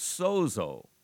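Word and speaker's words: sozo (σῴζω): You know, the word for saved is the word sozo. It's pronounced, that Greek word for save is sozo.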